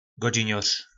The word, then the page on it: godzinor: po nasimu